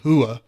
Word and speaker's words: Rua: Dan Hendo Henderson against Shogun Rua, who I pronounced Rua before, but you educated me.